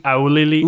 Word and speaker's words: Auli'i: I can't, yeah, she's got a kind of like Hawaiian pronouncing first name, spelled first name. Yeah, Auli'i. – Auli'i.